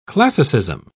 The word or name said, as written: classicism